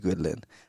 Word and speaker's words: Guidolin: Today, guys, we have the woman that you've all been asking for on the DMs, Sophie Guidolin. And I pronounced that right, didn't I? – You did. You nailed that.